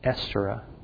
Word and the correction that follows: Astaroth: no, that's not the correct pronunciation, Astaroth.